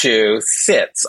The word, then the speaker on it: Massachusetts: No, the middle syllable is chew, like chew. On food. – Massachusetts. – Massachusetts on a seat.